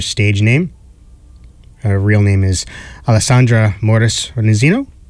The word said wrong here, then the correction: Reynoso: or Reynoso. That's how you pronounce it.